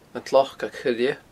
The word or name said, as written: An t-Slocag Choille